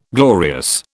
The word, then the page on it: glorious: British English